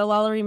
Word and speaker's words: Lalaurie: Honestly, the coolest thing, I think I went back on the way home, I will say, and tell me if I pronounce this wrong, but Madame Lalaurie? – Yeah, the Lalaurie Mansion.